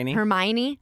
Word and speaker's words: Hermione: But I guess, how do you say her name again? Hermione. – Mm-hmm.